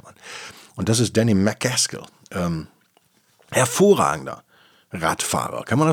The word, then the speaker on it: McCaskill: Danny McCaskill, der Schotte. McCaskill. Ähm, könnte man ihn noch, noch äh, schottischer aussprechen vielleicht. Deutsche würden ja sagen Mac Askill.